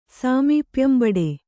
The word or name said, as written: ಸಾಮೀಪ್ಯಂಬಡೆ